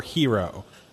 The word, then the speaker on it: gyro: Chris, the Greek food hero is most definitely not pronounced gyro. It is gyro or hero.